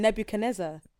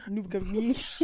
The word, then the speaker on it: Nebuchadnezzar: And just, you know, when they were thrown into the fire by King Nebuchadnezzar. – No, I'm tired. – Jesus, I can't say that. What is it? – Nebuchadnezzar. – You see, everybody says it different. – Wait, say what you said. Nebuchadnezzar.